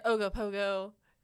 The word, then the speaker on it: Ogopogo: The Ogopogo, you know, like a pogo stick.